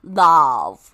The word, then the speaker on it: Lauv: Lauv.